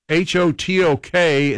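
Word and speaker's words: Hotteok: Otherwise known as Hotak. Hotok? – Hotok. – Hotok. – Yeah, Hotok is how you pronounce it.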